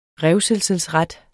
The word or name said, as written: revselsesret